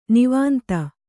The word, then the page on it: ನಿವಾಂತ: nivānta